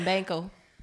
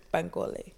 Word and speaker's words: Bankole: I be calling him Banco. I just get I do too. I say Banco, but because they talked about ethnic name, I was like, okay, Bankole. – Yeah, well, Drew's okay. Right. In the audiobook, that's how they say it.